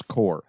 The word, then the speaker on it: Corps: Also, he calls the IRGC the Iranian Revolutionary Guard Corps, which seems weird. I feel like every military person in the world knows how to pronounce core.